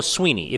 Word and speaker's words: Swinney: It's also Sweeney, not Swinney. – Sweeney. – I always thought it was Sweeney, right? – It is. It's Sweeney. Dabo Sweeney. If you look at it spelled, you'd think Swinney.